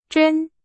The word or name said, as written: 真